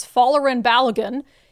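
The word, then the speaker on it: Folarin Balogun: So as you probably heard, one of our star players, Fallerin Balogun, is now going to be able to play.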